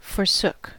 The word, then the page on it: forsook: American English